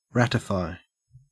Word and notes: ratify: Australian English

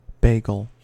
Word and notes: bagel: American English

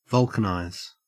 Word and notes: vulcanize: Australian English

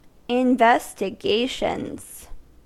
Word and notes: investigations: American English

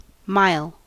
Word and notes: mile: American English

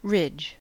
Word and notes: ridge: American English

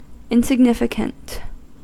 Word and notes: insignificant: American English

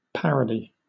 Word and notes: parody: British English

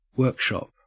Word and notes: workshop: Australian English

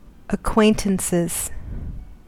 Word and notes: acquaintances: American English

